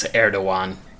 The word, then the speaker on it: Erdogan: Also, the G is pronounced like a W. It's Erdogan.